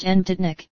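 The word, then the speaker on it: Nebotičnik: Nebatinik, pronounced Nebatinik,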